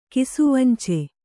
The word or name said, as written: ಕಿಸುವಂಚೆ